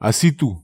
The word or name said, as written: asitu